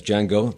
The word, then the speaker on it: Django: That's Jamie Foxx as Django, the D is silent,